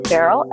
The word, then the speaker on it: Farrell: And Kongit Farrell is spelled K-O-N like fancy, G like girl, I, T like Tom. And the last name is Farrell, F like Frank, A-R-R-E-L-L.